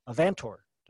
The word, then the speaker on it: Avantor: She is currently a life science specialist at VWR International, part of Avantor. Did I say that right, Eva? – Yes, that's correct.